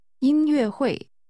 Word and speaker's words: 音乐会: 音乐会。会。音乐。音乐会。 – Concert, or literally, music meeting, is pronounced with a high-level tone. – 音 followed by two falling tones. – 音乐会。再说一次。 – 音乐会。 – 试试说。 – Yesterday evening, I went to a concert. Say literally, yesterday evening, I went to listen to a concert. – 昨天晚上我去听音乐会了。